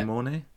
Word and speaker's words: De Mornay: Rebecca De Mornay, I believe that's how you pronounce it. Yeah. De Mornay? – Yeah. – Oh, go on. Nailed it.